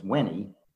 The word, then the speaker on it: veni: or I think to pronounce it properly, it's veni, is come, to come.